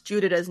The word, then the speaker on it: Judita: Like, I didn't know how to pronounce Judita's name, so now I know that it's Judita. – Uh-huh. – Because I was like, don't know what that is. – Yeah, I just have her in here as Judy so that I don't fuck it up. So. – Yeah, well, it's Judita. That's how you pronounce it, Judita.